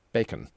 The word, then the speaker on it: BAC: But there is also now something that's referred to as, well, it's bland automated community notification, or BAC, and pronounced bacon as opposed to spam.